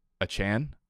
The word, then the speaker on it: Achane: I think the biggest takeaway here is that we've been saying Devon Achane's name wrong the entire summer. So it's Achane.